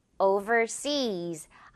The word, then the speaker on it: overseas: 그래서 overseas, 해외라는 명사 아니라는 거 기억을 해줘야 되겠고 발음은 뒷부분 seas 부분이 강세입니다. overseas, seas를 확 강하게요.